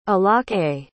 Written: alake

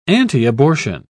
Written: antiabortion

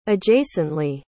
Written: adjacently

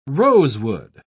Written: rosewood

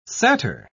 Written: satyr

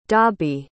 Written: dabi

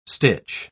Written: stitch

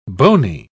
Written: bony

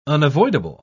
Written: unavoidable

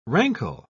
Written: rankle

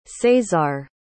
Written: Cesar

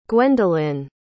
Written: gwendolin

